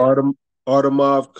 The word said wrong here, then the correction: Avtomat: Kalashnikov. – Avtomat Kalashnikov. Yeah.